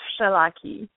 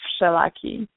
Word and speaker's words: Wszelaki: The W, you pronounce it as an F, so it's pronounced as Wszelaki.